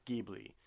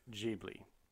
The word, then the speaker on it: Ghibli: It's apparently an Italian word in origin, and in Italian, it's Ghibli. – I was right. – But the Japanese pronounce it Ghibli.